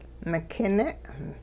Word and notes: mikine': Hupa

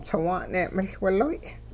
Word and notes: tsiwa'n-ne' miL-wiloy': Hupa

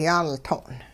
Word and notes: yaaltong': hupa

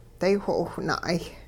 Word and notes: daywho'owh na'ay: Hupa

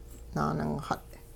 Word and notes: naaningxut': Hupa

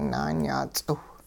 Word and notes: naanyaa ts'iw: hupa

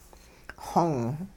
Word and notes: xong: hupa

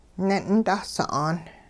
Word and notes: ne'in dah sa'aan: Hupa

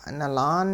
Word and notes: nilaan: hupa

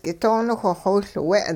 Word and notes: k'itooniwh xowiLwe': hupa

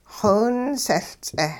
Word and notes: xowinsehL-ts'eh: hupa

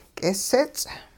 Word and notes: k'isits': hupa

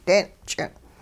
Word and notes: deen'ch'ing': hupa

